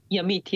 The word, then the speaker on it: Yamit: What was your childhood like in Yamit? Am I saying it correctly? – Yamit, yes.